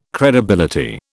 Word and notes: credibility: British English

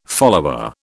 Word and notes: follower: British English

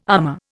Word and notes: armor: Indian

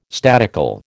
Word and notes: statical: American English

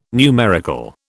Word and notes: numerical: British English

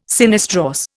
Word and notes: sinistrorse: Indian

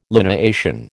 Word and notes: lineation: American English

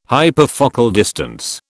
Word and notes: hyperfocal distance: British English

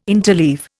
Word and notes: interleave: Indian

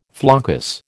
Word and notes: floccus: American English

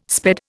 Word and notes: sped: Indian

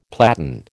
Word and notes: platen: American English

